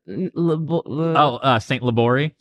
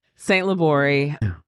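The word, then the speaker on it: St. Libory: It is from a local butcher, Wenemans, out in, how do you say that? – St. Labori. – Yeah, I can never say that right. St. Labori.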